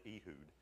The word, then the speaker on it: Ehud: Then the people of Israel cried out to the Lord, and the Lord raised up for them a deliverer, Ehud, or some people pronounce it Ehud, but we'll just say Ehud because I'm lazy, and the son of Gera, the Benjamite, a left-handed man.